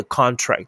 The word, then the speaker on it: contract: 念大声就可以，所以重音在前面，Contract，Contract，Side Contract，这叫做合约，重音在中间，Contract。 contract 叫收缩，所以 contract 在前面是合约，contract 才是收缩。